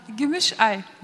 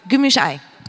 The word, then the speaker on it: Gümüşay: Wenn ich es immer noch nicht richtig ausgesprochen habe, musst du mir nochmal helfen. Gümüşay. Gümüşay.